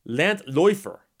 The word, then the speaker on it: Landläufer: In High German, laufen becomes laufen, and from that stem grew the word Landlaufer, which is a tramp or a bum, pronounced Landläufer.